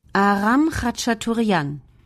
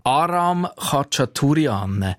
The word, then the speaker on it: Aram Khachaturian: Aram Khachaturian. – Aram Khachaturian.